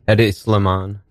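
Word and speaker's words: Hedi Slimane: Eddie Sliman.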